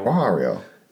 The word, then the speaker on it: Wario: Wario, if I'm saying that right. – Wario.